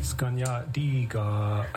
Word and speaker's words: Skunyatiga: Skunyatiga. – Heron. – Did I pronounce that correctly? – Skunyatiga. – Skunyatiga. Otahioni.